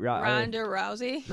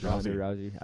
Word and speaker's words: Ronda Rousey: Let's give a shout out to Ronda Rowdy. What's her name? Rowdy Rodna. – Ronda Rousey. – Ronda Rousey.